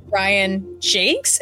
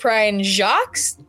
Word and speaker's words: Brian Jacques: And you know, I've just recently heard, and I don't know if this is true because Homeboy's dead, but the guy who wrote Redwall apparently pronounced his name Brian Jakes instead of Brian Jacques.